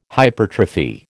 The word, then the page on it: hypertrophy: American English